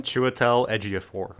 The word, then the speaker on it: Chiwetel Ejiofor: And then how do we say his name? – Chiwetel Ejiofor.